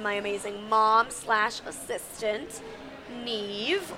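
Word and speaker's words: Niamh: Yeah, so it's N-I-A-M-H and pronounced N-E-V-E. Super confusing for people.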